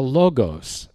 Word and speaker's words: Logos: It's the Logos, L-O-G-O-S, is the word for word that's used here.